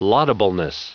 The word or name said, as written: laudableness